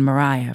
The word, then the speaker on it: Mariah: The story also goes that I was named after a hit 1950s show tune they call The Wind Mariah from Paint Your Wagon, a Broadway show about the California Gold Rush. Both references use the soft pronunciation with the second syllable having a ry sound.